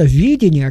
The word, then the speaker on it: видение: Я настаиваю на ударении на первый слог этого слова, потому что видение, о котором мы сегодня говорим, отличается от видения.